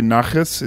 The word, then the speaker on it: nachas: Do I say nachas? Is that the way of pronouncing it? – That's correct, yeah.